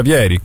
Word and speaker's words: Vieri: Peraltro, caro Cecca, anche il buon Francesco Benvenuti ci scrive che si pronuncia Vieri, quindi...